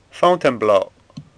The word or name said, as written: Fountain